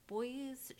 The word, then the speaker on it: Boies: and I believe it was called Boies, Schiller, and Flexner, LLP. I may have butchered that name. It's B as in boy, O-I-E-S, Schiller and Flexner.